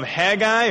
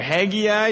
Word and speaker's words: Haggai: It comes from the book of Haggai, or Haggai, depends how you want to pronounce it.